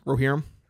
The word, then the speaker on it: Rohirrim: Rohirrim.